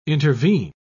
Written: intervene

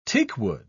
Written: teakwood

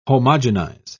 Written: homogenize

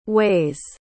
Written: weighs

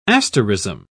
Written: asterism

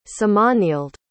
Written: samanilde

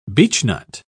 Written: beechnut

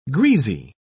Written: greasy

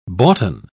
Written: boughten

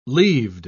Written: leaved

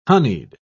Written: honeyed

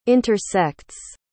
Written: intersects

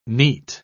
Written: neat

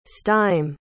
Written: stime

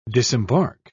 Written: disembark

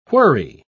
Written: whirry

